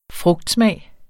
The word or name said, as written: frugtsmag